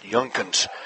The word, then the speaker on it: Jungkunz: And Bryce's last name is spelled J-U-N-G-K-U-N-Z, pronounced Yunkins.